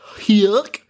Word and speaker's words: Huyck: Directed by Willard Hyuk. That's how it's pronounced. – I mean, Come on, that's literally Goofy. – H-U-Y-C-K.